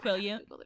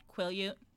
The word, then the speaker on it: Quileute: Quileute. – What? – Quileute. – Quileute? – At least that is how it is pronounced in the movies.